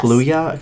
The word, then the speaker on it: Kaluuya: Daniel Kaluuya. – Yes. Kaluuya.